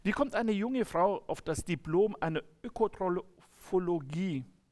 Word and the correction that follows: Diplom-Ökotrophologe: Also ich habe bin Diplom-Ökotrophologe, so wird es ausgesprochen.